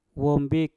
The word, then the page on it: wom bik: mpur